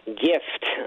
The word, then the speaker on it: Gift: But I also play on the German word gift, pronounced gift, actually means poison in German, right?